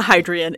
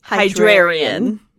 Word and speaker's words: Hydrarian: We missed a whole syllable. It's not Hydrian. It's Hydrarian.